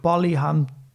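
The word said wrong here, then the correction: Palihapitiya: Shamat Palihapitia.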